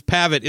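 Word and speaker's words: Pavitt: Bruce Pabbitt is the correct pronunciation, right? – That is the correct pronunciation.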